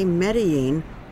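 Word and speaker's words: Medellín: These are the Andes Mountains and lush countryside of Antioquia, Colombia, whose capital city, Medellin, was once famous for murder and the drug cartel of Pablo Escobar. Today, Medellin, or Medellin as it's pronounced here, is peaceful.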